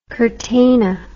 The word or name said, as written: curtana